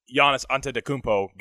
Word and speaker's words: Giannis Antetokounmpo: And I'm glad that Giannis Antetokounmpo got it.